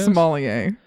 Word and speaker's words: sommelier: How do you pronounce that word? Sommelier? Is that how it is? – Sommelier.